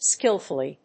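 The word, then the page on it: skilfully: skil･ful･ly; skɪ́lf(ə)li; British English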